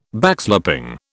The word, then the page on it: Backslapping: British English